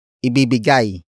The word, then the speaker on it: ibibigay: Word for word it's ibibigay kita ng regalo. Ibibigay kita ng regalo. Ibibigay kita ng regalo. The first word is ibibigay.